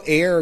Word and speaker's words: Aire: It's spelled A-I-R-E. And Kylie clarified that A-I-R-E is pronounced air, which is fine. It could have a silent E.